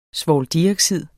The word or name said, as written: svovldioxid